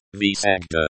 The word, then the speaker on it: всегда: It is pronounced as visegda.